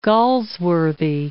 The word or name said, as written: Galsworthy